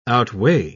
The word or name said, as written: outweigh